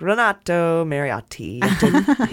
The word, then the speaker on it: Renato Mariotti: could say Renato Mariotti, but I'm like, Renato Mariotti. Hit those T's hard.